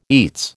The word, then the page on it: eats: American English